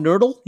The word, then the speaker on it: nurdle: No, but I can tell you what it rhymes with. – Okay. – Hurdle. – It's called a nurdle. – Yeah. – Nurdle? – Yeah. – Nurdle. – Nurdle. – Like a turtle, but with an N.